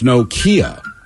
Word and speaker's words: Nokia: Somebody says, I pronounced it wrong. It's Nokia, like the car. Two syllables, not Nokia. Nokia.